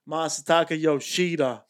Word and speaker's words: Masataka Yoshida: And the guy who should have been the MVP – future Yankees killer, Masataka Yoshida, Boston Red Sox.